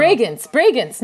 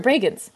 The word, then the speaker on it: Bregenz: Oh, Bregenz. How do you say it? I don't know. You know what I'm talking about? – Bregenz. No, you're right. Bregenz.